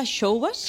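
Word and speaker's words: Xoubas: Esto se pronuncia Showbas. – Showbas, sí.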